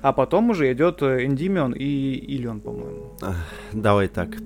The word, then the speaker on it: Эндимион: Ты говоришь про Гиперион и переход э, к Эндименю. – Да. – Как Эн, Эндимион. – Эндимион. Эндимион, правильно, ударение, да.